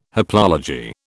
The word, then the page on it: haplology: British English